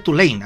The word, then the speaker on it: Tulane: La Universidad de Tulane, o la Universidad Tulane, así se dice.